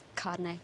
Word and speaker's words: Chardonnay: Mum, it's French. The H is silent. Back me up here, Kylie. – Yeah, she's right, Mrs. Deer. I've been to Paris and the H is silent. It's Chardonnay.